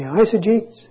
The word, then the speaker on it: eisegetes: Did somebody say eisegetes? Oh, viva. Thanks for remembering. E-I-S-E-G-E-T-E-S. Eisegetes. And the key syllable there is the first one, E-I-S, which is the Greek for in, or better, into.